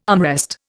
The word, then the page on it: armrest: Indian